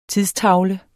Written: tidstavle

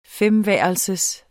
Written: femværelsers

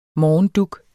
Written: morgendug

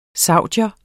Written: saudier

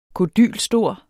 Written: kodylstor

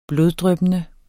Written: bloddryppende

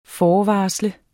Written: FORVARSLET

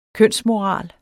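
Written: kønsmoral

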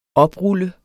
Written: oprulle

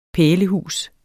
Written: pælehus

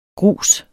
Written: grus

